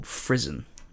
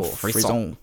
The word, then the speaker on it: frisson: Within music, because he basically talks about a reaction that you may have or you may not have called Frisson or Frisson. – Yeah. – Derives from a French word. We like to say it like that.